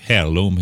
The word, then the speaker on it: heirloom: Vi kan, ett eh, heirloom heter det ju på engelska eller något liknande. Du får säkert rätta till uttalet som vanligt där. ja, det går bra. Det, det var, var det inom godkänd ramen.